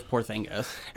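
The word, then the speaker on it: Porziņģis: I can't pronounce his name right, but yes, I believe it is Porzingis.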